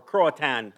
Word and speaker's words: Croatoan: Yeah, and it's from the south, the Croatoan, Croatoan is what they were calling them, or Croatan. I've seen it pronounced that way.